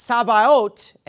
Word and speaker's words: Sabaoth: It's pronounced Sabaoth, and it means the Lord of hosts, right?